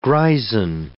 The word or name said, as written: greisen